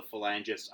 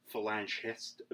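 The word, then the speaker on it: Falangist: The the I call them the Falangists. I believe it's actually pronounced phalangist.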